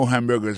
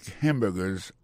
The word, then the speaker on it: hamburger: N'a pas remarqué que dans le mot hamburger, ça a comme tu as dit, il y a trois syllabes là-dedans. Quand tu appuies et puis ça, c'est sous premier syllabe là. I make hamburgers all day.